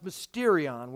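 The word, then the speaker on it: mysterion (μυστήριον): In the Greek, it's pronounced mysterion, where we get the English word mystery.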